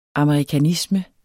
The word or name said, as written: amerikanisme